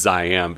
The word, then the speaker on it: Ziam: Which And at first I was like, how do you pronounce that? Like, you know, I'm probably going to mispronounce, but it is Siam because it's referring to the former name of Thailand, Siam, with a Z for zombies. Oh, clever.